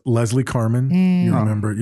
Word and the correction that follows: Carman: The thing is, everybody pronounces her last name wrong. It's actually Carman.